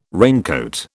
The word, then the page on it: raincoat: British English